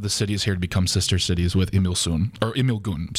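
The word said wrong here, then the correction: Imsil-gun: Is that how you pronounce it? Did you look it up? – Oh, no. It's actually Imsilgun.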